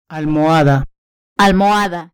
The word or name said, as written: almohada